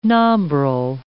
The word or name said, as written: nombril